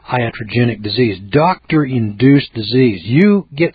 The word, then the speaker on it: iatrogenic: Looking here in the article, iatrogenic, I-A-T-R-O-G-E-N-I-C, iatrogenic, odd term.